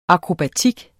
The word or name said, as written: akrobatik